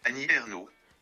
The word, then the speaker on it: Annie Ernaux: Annie Ernaux.